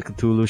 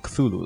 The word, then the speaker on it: Cthulhu: Like, that's the actual pronunciation for Cthulhu. Like, it's K-L-U-L-U. You have it's meant to be pronounced like two syllables.